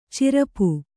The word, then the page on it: ಚರಪು: Kannada